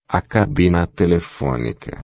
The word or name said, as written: cabina telefonica